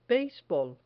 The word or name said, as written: beisbol